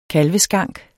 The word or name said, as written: KALVESKANK